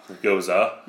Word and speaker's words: Gose: Hey, the one I got is a Gose. – Nice. – Okay, so Goza. Yeah, if you look on the pronunciation key, this one is very grammatically correct.